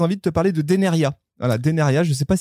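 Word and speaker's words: Dénériaz: Et vous remarquerez que Deneria, il y a un Z à la fin. Ça ne se prononce pas, chers amis. Voilà, comme ça, vous le saurez. Antoine Deneria, eh bien, il est haut savoyard, et champion olympique de descente en 2006 à Turin.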